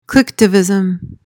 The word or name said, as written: clicktivism